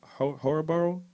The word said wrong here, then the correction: Hillsborough: County? I don't know if I'm saying that right. – Never heard of it. – Hillsborough. Okay. Yeah, there you go.